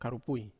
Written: karipui

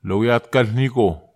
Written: lowiatkelniko

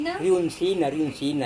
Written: riunsina

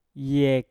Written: yek

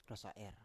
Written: rosa7er

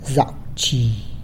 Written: Zac wzhi

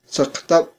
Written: saqtab

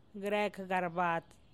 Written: grekgarbat

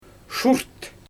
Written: ʃurt

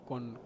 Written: kon

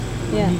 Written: mani

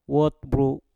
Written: wot bru

